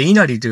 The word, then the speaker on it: 稲成: あれを書いて、稲がなると書いて、稲荷と読むんです。実はこのさっきから言ってる全国に一番数多い神社なんじゃないかという、この稲荷神社の中で、このなるという字を書いて稲荷と読むのは、ここ一社だけと言われているんですね。